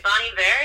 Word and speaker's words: Bon Iver: I can never say the name right. Bon Iver, I think? – Yeah, you got it.